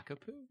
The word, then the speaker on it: kakapo: There's a bird called a kokopo. I'm probably not pronouncing that correctly, but it's a cute name, like kokopo or kakapo. Yeah. – Kakapo? – Kakapo.